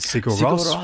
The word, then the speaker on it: Sigur Rós: And being able to sort of, you know, pull the Sydney Symphony Orchestra together with Sigur Rós from Iceland.